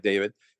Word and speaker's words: Daved: I pronounce it like David, and it's spelled D-A-V-E-D.